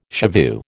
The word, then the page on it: shivoo: American English